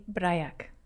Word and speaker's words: Brajak: So please, how do you pronounce your last name? – Technically, Brajak. – Brajak. – Yes.